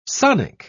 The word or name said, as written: sonic